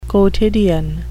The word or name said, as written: quotidian